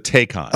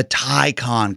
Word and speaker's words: Taycan: The Porsche one is the Taycan. – Oh, my God. – Or it's the Taycan, because I say Taycan as well.